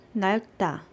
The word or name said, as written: nalkda